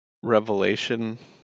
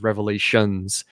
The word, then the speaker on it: Revelation: Right away, you know Joel means business, because one of the most common mispronunciations is revelations. And It drives me crazy. Absolutely drives me crazy when people say that.